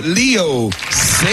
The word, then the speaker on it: Leo Sayer: The one and only Leo Sayer.